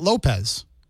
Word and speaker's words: Lopes: Here, L-O-P-E-S is Lopes. Somewhere else, they say that Lopez.